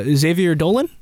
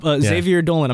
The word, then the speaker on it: Xavier Dolan: Okay, so the list we have is Xavier Dolan.